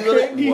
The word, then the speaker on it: Grande: Grande. – Wow, hey, she Grande. – Said, hey, that's how you pronounce it for real.